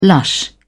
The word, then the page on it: lush: British English